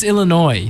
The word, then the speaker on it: Illinois: Like, I hate when people say Illinois with the S. Ooh. Like, you know it's Illinois. Say it right.